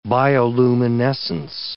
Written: bioluminescence

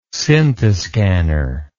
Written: scintiscanner

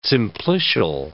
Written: simplicial

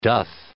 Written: doth

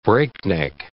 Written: breakneck

